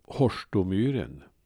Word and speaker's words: Horstomyren: Stavningen kan ibland förvilla uttalet som i det här fallet då det första oet blev till ett kort å-ljud, typ Horstomyren. Med stöd av liknande namn,